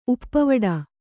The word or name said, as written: ಉಪ್ಪವಡ